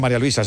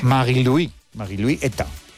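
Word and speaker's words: Marie-Louise Eta: No se dice María Luisa, se dice en su... – Sí. – Marie-Louis Eta.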